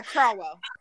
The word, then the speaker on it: Craughwell: His last name is spelled C-R-A-U-G-H-W-E-L-L. But yeah. – Yeah, Crowell.